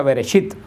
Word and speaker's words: Bereshit: Por eso el libro de Génesis en hebreo se pronuncia Bereshit, que es la primera palabra que sale de la revelación de Dios, que quiere decir en el principio.